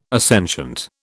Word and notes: assentient: British English

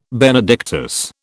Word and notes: Benedictus: British English

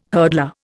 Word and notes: hurdler: Indian

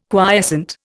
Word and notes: quiescent: Indian

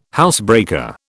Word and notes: housebreaker: British English

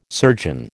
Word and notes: surgeon: American English

